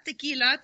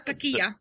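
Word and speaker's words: Taqiyya: Yeah, not tequila, Takiya.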